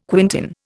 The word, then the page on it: quintain: Indian